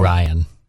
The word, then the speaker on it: Orion: The First Orion. So, did I say it right? – No. – Orion. – Oh, I said it wrong again. Sorry. Well, it's like Orion's belt.